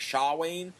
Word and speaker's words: Samhain: The actual word is like Shawain, so it should have been translated like S-H-A-W-E-I-N.